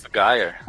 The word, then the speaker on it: Top Gear: Ó, que bonita hum, a pronúncia. Top Gear.